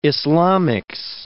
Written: Islamics

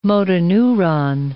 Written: motoneuron

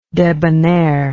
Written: debonairness

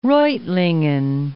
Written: Reutlingen

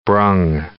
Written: brung

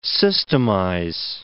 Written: systemize

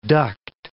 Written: duct

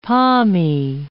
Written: palmy

